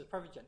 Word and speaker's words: Prevagen: Prevagen. – They were selling. They were selling this as Prevagen.